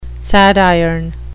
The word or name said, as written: sadiron